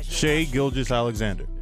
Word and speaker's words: Shai Gilgeous-Alexander: Shai Gilgeous-Alexander.